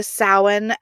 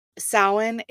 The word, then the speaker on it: Samhain: Now, Samhain is spelled Samhain, and the M and the H produce that W.